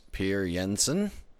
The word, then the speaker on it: Per Jensen: The proper way to say it is Per Jensen, right? – Yep.